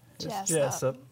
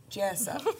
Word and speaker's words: Jessup: Jessup. – Jessup. You got to kind of get in there with it. You know? – It's Jesus with a P.